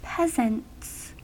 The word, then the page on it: peasants: American English